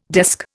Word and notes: desk: Indian

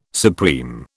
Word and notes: supreme: British English

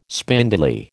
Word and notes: spindly: American English

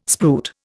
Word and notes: spruit: Indian

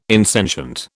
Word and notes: insentient: British English

